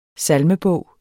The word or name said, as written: salmebog